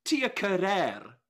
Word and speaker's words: Tia Carrere: I love the way Alan Dedicote says her name. Tia Carrere. Like, he rolls his R's. Loved it every time. So, Tia Carrere, who we are now finally pronouncing her name correctly, as we bid adieu to.